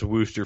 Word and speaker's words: Worcestershire: I believe it's actually pronounced Wooster, from what I understand.